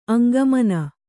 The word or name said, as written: ಅಂಗಮನ